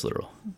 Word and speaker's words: littoral: No, I think it's literal. – Okay. – I just mispronounced it. Yeah, I actually Googled how to pronounce it like today, and it was just literal.